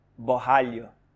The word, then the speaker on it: Borralho: Borralho.